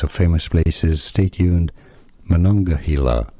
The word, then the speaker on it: Monongahela: The Monongahela. – Hey, Pete, can we get a pronouncer on this? – Monongahela.